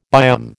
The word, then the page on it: biome: American English